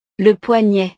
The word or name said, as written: le poignet